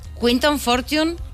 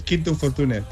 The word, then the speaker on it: Quinton Fortune: Quinton Fortune, ¿lo hemos pronunciado bien? – Sí, sí. Bueno, okay. Normalmente en España es Quinto Fortune, pero es igual.